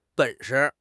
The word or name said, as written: 本事